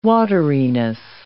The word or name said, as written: wateriness